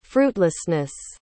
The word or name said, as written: fruitlessness